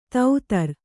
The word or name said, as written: ದಾದರಾ